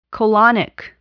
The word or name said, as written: colonic